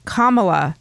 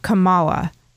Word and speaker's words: Kamala: It's pronounced Kamala, not Kamala. Thank you for everything you do.